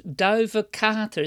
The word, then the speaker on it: Duivekater: It is called Duive Kater. It's spelled D-U-I-V-E K-A-T-E-R.